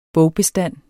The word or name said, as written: bogbestand